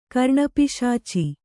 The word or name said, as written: ಕರ್ಣಪಿಶಾಚ